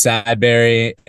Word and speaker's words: Sadberry: My last name is Sadberry, and I whenever I have to tell people how to spell that, I say just picture strawberry having a bad day.